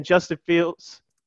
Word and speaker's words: Justin Fields: They had it over the weekend, what their their rookie mini camp, and Justin Fields.